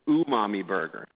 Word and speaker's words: Umami Burger: You know, there's 100 different pronunciations, but it's actually Umami Burger.